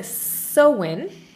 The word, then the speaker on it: Samhain: Now, I pronounce it Samhain, and as I was looking at it, I was completely wrong. So, Samhain is how it's pronounced.